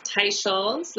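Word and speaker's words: Teicholz: It's pronounced Tai Scholes, like a tie you wear in Dr. Scholes' shoes.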